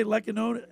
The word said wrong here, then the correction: Lehkonen: Lehkonen. – Lehkonen.